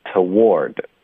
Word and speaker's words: toward: 后面呢是我们的重音的音节，它就不能变音，它不能弱读，它发 word。那这就是一个窝，带一个儿化音啊。 OK，所以是 war，有一点像 war 战争的这个发音一样，再加一个 the toward。